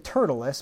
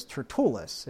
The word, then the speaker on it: Tertullus: I said Tertullus, but I think actually his name was Tertullus, if you look at the accent there.